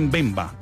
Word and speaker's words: Mbemba: Bueno, Calcame dice que es Mbemba. Con Mbemba, acento en la E.